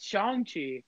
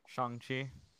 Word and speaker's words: Shang-Chi: But the actor himself said it's Shang-Chi. – How is it pronounced in the trailer? – Shang-Chi. – It's fucking Shang-Chi, absolute imbeciles. – I got the ad on Spotify earlier today. He said Shang-Chi.